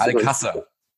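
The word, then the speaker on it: Alcácer: Übrigens, wie wird der denn nur ausgesprochen? – Das hast Al-Kasar. – Al-Kasar. – Ja. – Ich dachte Al-Kasar, aber gut. – Ja. – Dann haben wir wieder was gelernt.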